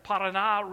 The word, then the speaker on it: Paraná: They pronounce it there, the Paraná River.